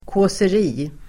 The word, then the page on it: kåseri: [kå:ser'i:]